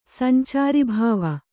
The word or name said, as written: ಸಂಚಾರೀಭಾವ